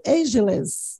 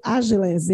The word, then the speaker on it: ageless: A pronúncia é uma palavra inglesa, o estilo ageless, mas que no Brasil acaba sendo o estilo ageless, é isso? – É isso mesmo. Até eu gostei, porque o Agiles parece algo ágil, né?